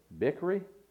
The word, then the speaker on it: Bichri: Now David said to Abishai, now Sheba the son of Bichri, I hope I'm pronouncing that right. Bichri. What's it? – Bichri.